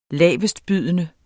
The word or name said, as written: lavestbydende